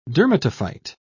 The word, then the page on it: dermatophyte: British English